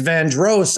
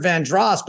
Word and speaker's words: Vandross: Well, to clarify, my particular issue wasn't Dross or Dross. It was the accent on the second syllable. – Oh, Van. – Luther Vandross or Luther Vandross, but not you were going.